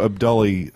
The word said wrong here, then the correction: Abdullahi: I'm mispronouncing your name, Andrew. – Abdullahi.